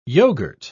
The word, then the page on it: yoghurt: British English